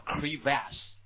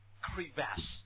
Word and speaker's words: crevasse: 好，next one，下个词来叫 remains， preservation， silt, silt, setting, body, scavenger, scavenger, numerous, field mice, vole, decompose, inaccessible, ice, 这个怎么读 crevasse, OK, 记一下，这个音标比较难受啊，叫 crevasse, yes, crevasse, right, 啊，就是后面重音在后面 crevasse,